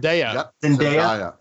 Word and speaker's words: Zendaya: I like the actress, Zendaya. Is that how I'm going to say her name right? – Zendaya.